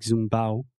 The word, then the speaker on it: zumbado: And then I saw on the worksheet they explained, okay, this is actually zumbado, and we say it like zumbao, and it's a very informal expression that means like kind of crazy and buzzed.